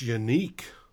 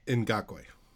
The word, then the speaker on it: Ngakoue: But that trade for, and if I butcher this name, I apologize, Yannick Ngakoue, is that how it's pronounced? – It's unique. – Unique? – Ngakoue.